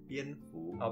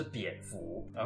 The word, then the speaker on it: 蝙蝠: 对，这里可能先补充一下，就是蝙蝠的读音其实是蝙蝠，哦，不是蝙蝠。